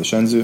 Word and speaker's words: Shenzhou: is the first officer right now in the Shenzhou.